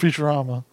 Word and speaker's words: Futurama: Futurama.